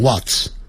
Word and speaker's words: what's: is 连在一起，我们就把 i 去掉，改成一撇，what 加一撇加个 s，念的时候念成 what's，在英式英语会念成 what's，可是在美式的时候，它那个 h 的音它就不发出来，只是念成 what 加一撇加 s，念成 what's，就是 what is 的缩写。